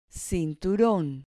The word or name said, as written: cinturon